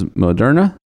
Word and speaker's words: Moderna: But we have seen some interesting stocks going up, and I'm probably going to butcher this company's name, but the first one is Moderna. – Yes. – Is that correct? – Yep.